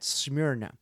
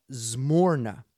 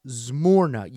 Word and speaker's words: Smyrna: But uh, here's how you can pronounce it according to to the to the Greek translators who read this. All right, it's the church at Zmorna. All right, the church at Zmorna.